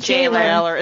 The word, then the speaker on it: gaoler: Is it gaoler? How's that pronounced? – Jailer. – It's just jailer.